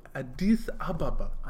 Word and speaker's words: Addis Ababa: Pronounce it properly. Addis Ababa.